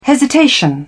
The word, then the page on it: hesitation: British English